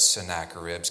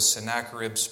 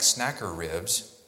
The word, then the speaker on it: Sennacherib: Last time I spoke, I was trying to be very careful with Sennacherib's pronunciation so it didn't sound like snacker ribs.